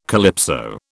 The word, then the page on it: calypso: British English